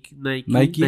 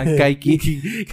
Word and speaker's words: Nike: Nike. Nike. Decía Kike, güey. Kaiki.